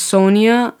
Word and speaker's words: Sonia: Like, my name on Instagram is just Sonia Saudetsmand. It's just my name and my family name. Like, S-O-N-I-A, S-A-A-D-A-T-M-A-N-D.